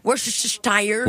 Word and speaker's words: Worcestershire: La fameuse sauce Worcestershire.